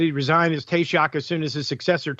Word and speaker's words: Taoiseach: The report says T-A-O-I-S-E-A-C-H. Looks like Taoiseach. It's Taoiseach. That's how it's pronounced.